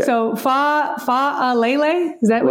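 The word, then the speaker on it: Faalele: I've been using Falele, but I am told it is Fa'alele, even though I don't believe he has the apostrophe in between the two A's at the beginning of his name.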